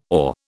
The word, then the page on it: OR: British English